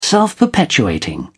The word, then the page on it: self-perpetuating: British English